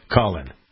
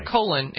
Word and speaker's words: Colin: Colin. – important. It's Kaepernick. Never colon. It's never colon. It's never your intestine.